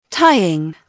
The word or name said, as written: tying